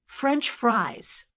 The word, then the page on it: french fries: American English